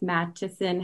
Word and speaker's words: Mattison: and that's Mattison Heatherly. And yes, my name is spelled weird. It's not Madison.